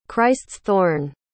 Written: christ's-thorn

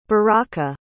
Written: baraka

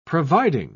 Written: providing

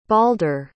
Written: balder

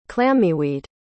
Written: clammyweed

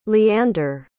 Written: leander

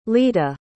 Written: lita

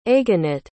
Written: aganit

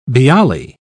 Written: bialy

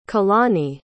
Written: kahlani

